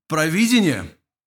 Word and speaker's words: провидение: Если вы поставите ударение на второй слог «провидение», то у вас получается смысл видеть что-то наперед или что-то предвидеть.